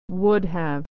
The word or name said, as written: would have